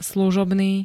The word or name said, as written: služobný